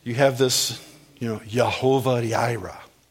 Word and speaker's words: Yehovah Jireh: You may say Jehovah Jireh. I used to say that until I heard some Hebrew guy say it, and then I thought, oh, I've been saying it wrong my whole life.